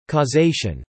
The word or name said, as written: causation